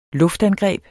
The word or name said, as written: luftangreb